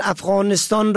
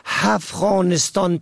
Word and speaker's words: افغانستان: مثلا افغانستان را که با الف شروع می شود با ه دو چشمه هفغانستان تلفظ می کنن.